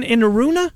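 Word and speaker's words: Enaruna: How do you say his name? Inaruna? Is Tristan Inaruna? – Yep, you nailed it right there.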